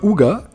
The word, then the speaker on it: hygge: Oui, que l'on écrit H-Y-G-G-E, mais les Danois le prononcent Ouga.